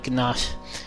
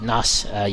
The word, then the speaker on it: gnat: Now I know what you're thinking, the G in that is silent, but I wanted to say the G anyway, just so it was clear what I was saying, because if I just said gnat,